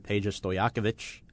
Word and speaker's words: Stojakovic: Former player Peja Stojakovic is the new assistant general manager.